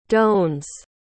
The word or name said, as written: dosne